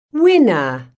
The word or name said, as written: winner